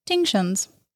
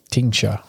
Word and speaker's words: tingsha: And then these are called Tingshans. – How do you spell that? – T-I-N-G-S-H-A. – Tingsha. – Tingsha. Okay. Yeah, the A goes way up there.